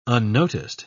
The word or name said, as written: unnoticed